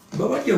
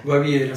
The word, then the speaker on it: Baviera: ¿Bavaria o Baviera? ¿Cómo se dice en castellano? Siempre me confundo el italiano con el español. – Yo lo sabía como... Baviera. – Baviera.